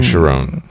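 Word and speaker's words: Cherone: Gary Sharon. Spelled the same way that you spell maraschino with C-H.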